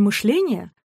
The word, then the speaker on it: мышление: Например, в слове «мышление» ударение перешло с корня на суффикс, а в словах «глажение», «квашение», «обеспечение» осталось на своем месте.